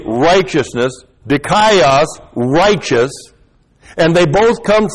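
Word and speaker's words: dikaios (δίκαιος): D-I-K-A-I-O-S-U-N, long E, dikaiosune.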